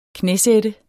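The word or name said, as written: KNÆSAT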